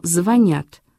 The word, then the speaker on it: звонят: И она стала отвечать про то, что взрослые едят и по телефону звонят с ударением на «я», и что все эти уменьшительно-ласкательные сюсюканье — признак инфантилизма и безответственности.